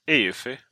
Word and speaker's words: Eufy: E-U-F-Y. EUFI brukar jag uttala det som.